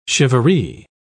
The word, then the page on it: charivari: British English